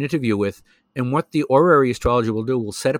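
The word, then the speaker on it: horary: There's another branch of astrology called orary astrology, which is spelled with an H, orary.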